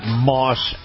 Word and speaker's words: Maus: And by the way, this portion of the program brought to you by our friends at Moss Acura. Now, Moss is spelled M-A-U-S,